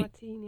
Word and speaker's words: Martine: Where Tucker, is it Martine? Is that how you pronounce his name, right? – Martine, yeah.